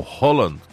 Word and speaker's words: Haaland: Haaland é o Holland, como é, Holland, né, que é o nome correto, o pessoal está corrigindo, tem as pronúncias dos jogadores, né?